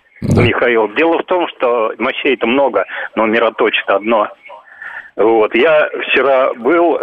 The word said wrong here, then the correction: мироточит: Можно я скажу мироточит, по-моему, ударение? Да, ударение мироточит. Да. Мироточит.